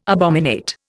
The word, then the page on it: abominate: Indian